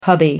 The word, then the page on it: hubby: American English